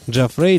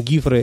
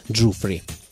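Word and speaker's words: Giuffre: И неудивительно, далеко не все американцы знают, как правильно произнести эту старинную сицилийскую фамилию. Многие ее носители в США произносят ее по-разному. Джафре, Джуфре и даже Гуфре и Гифре. Собственно, Гифре – это подлинное итальянское чтение. Сам музыкант когда-то положил конец этим разногласиям, прямо указав, что в его семье фамилия читается Джуфри.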